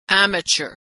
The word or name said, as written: amateur